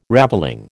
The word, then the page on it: ravelling: American English